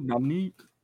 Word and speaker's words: Dominique: Oh, Dominique, that's the name, Dominique? – Yep. – Oh, sorry, bro. That's racist of me, right, to say Dominic? – That's just how to pronounce, man. You want me to say Nicholas Bolin or Nicolas? – Yeah, that's true.